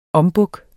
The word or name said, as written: ombuk